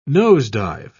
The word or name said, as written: nosedive